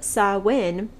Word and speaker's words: Samhain: So, Halloween had its origins in the festival of Samhain, which I had to look up how to pronounce because it's spelled S-A-M-H-A-I-N. Samhain. And I was like, I feel like that's probably pronounced differently. I did not expect it to be Samhain with a W.